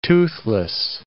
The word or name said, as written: toothless